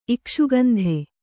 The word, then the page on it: ಇಕ್ಷುಗಂಧೆ: ikṣugandhe